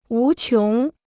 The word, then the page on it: 无穷: wú qióng